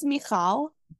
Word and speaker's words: Michal: We're saying your name correctly, right? – Yeah, well, close. it's Michal.